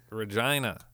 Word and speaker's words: Regina: Regina. That's how it's pronounced. – Okay, what are you getting all crazy for? – You asked me what I said. It looks like Regina, but it's pronounced Regina. – For Regina? – Regina. – Okay. – Rhymes with vagina.